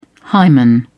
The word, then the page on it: hymen: British English